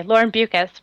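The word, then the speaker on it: Beukes: will tell you, like, if you ever meet her, she'll say, Lauren Bukes rhymes with mucus.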